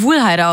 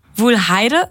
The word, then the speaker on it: Wuhlheide: Ich find's übrigens lustig, wie du Wuhlheide ausgesprochen hast. – Wuhlheide, was sag ich denn? – Wuhlheide? Wuhlheide. – Die Wuhlheide. Ich sag mal Heide eher.